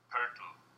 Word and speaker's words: Poeltl: So, my first name is pronounced Jakob, and the last name is a little bit more difficult. It's Poeltl. That's how you would say it in German.